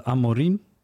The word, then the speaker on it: Amorim: Dat geldt voor nog een stuk Potter, Rodgers, Lopetegui, Amorim, zeg ik dat goed? – Ja, Amorim was volgens mij ook in beeld van Sporting.